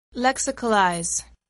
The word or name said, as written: lexicalize